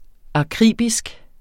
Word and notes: akribisk: ɑˈkʁiˀbisg; aˈkʁiˀbisg

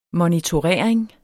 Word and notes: monitorering: mʌnitoˈʁεˀɐ̯eŋ

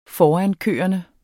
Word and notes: forankørende: ˈfɒanˌkøˀʌnə; -ˌkøːʌnə

